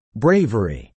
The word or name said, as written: bravery